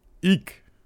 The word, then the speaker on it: Eick: How do you pronounce this? E-A-C-K? E-I-C-K. – Eek. – All right. Eek.